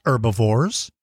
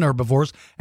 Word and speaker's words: herbivore: Omnivores are just as important as carnivores and herbivores. And if you're really young, an omnivore is an animal that eats plants and meat. An herbivore, or you might pronounce it herbivore, is an animal that only eats plants.